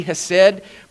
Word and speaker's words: hesed: It's spelled H-E-S-E-D, hesed. But if you're pronouncing it in Hebrew, you have to pretend like you're a character in Fiddler on the Roof, and you have to say hesed. You know, you have to go deep from down in.